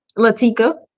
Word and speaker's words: Lateka: Is it Latia? – Lateka.